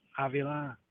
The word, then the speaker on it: Avila: Alex Avila, or how do you pronounce that again? – Yeah, you're Avila. Yeah, you were right.